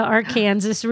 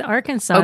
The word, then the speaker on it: Arkansas: Yeah, because Arkansas is pronounced wrong. – Arkansas is wrong because when you say Kansas, it is Kansas.